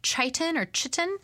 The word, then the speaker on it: chitin: There's also, I think it's pronounced chitin. Yeah.